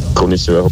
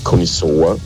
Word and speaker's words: Connoisseur: Connoisseur, habt ihr falsch gesagt, weil das heißt Connoisseur und die alten Schweden waren Fischer.